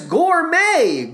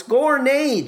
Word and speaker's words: Gournay: I mispronounced Sugar Babies cast member Debbie Gournay's surname as Gourmet. Gourmet? No, no, no, it's Gournay.